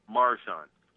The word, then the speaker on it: Maruchan: But, you know, the Marchurin, I don't know how to pronounce that. Look at the label. – Oh, the Maruchan? – Maruchan.